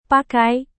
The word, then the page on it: pakai: Indonesian